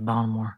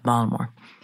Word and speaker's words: Baltimore: My husband's from Baltimore, or as I say, did I say it right? Baltimore. There's no T when they say it, Baltimore.